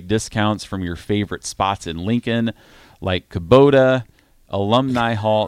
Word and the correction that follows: Qdoba: Did I pronounce that wrong? – Qdoba. – Qdoba.